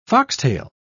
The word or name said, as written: foxtail